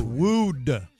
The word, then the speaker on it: wooed: It's woed. – It's wooed. It's spelled W-O-O.